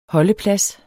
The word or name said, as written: HOLDEPLADSEN